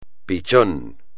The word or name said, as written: pichón